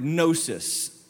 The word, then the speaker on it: gnosis: It comes from the Greek word gnosis, spelt with a G, G-N-O-S-I-S.